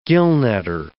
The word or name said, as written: gillnetter